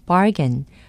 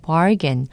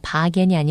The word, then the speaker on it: bargain: bargain, B-A-R-G-A-I-N, bargain, R 발음을 해주셔야 됩니다. bargain이 아니라 bargain.